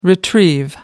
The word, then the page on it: retrieve: American English